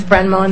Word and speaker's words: Brendmoen: It's Brenmoen, spelled B-R-E-N-D-M-O-E-N, but can pronounce it Amy.